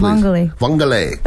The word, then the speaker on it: vongole: Vongole. But how you say it is like you're giving it like a Mexican spin. – Vongole. – Yeah, like it sounds like orale.